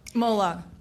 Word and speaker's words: Moloch: Especially here in the belly of, I know I'm saying it wrong. – Moloch. – Moloch, yes. Yeah. I've got the consonants all mixed up.